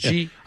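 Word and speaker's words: Xi: X-I pronounced G.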